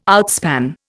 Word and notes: outspan: Indian

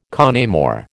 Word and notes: con amore: American English

